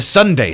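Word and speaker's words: sundae: The Sunday sausage. And that's spelled like the ice cream cone.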